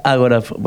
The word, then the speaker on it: agorafobia: Algunos le dicen que es una agorafobia. – Agorafobia. – Agorafobia, bueno, con acento en la... ponle el agora griego. Agorafobia, así, sí. Bien dicho.